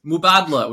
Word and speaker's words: Mubadala: Mubadala, which is how you pronounce it.